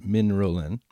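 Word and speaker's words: Mindrolling: And then it was only later that we understood that there was this lineage, which is actually pronounced Min Rolin, but is mind rolling.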